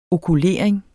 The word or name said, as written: okulering